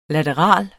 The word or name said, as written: lateral